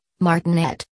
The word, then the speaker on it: martinet: Pronounce martinet with the accent on the last syllable, martinet.